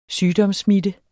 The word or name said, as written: sygdomssmitte